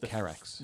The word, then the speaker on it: Carax: It's Carax.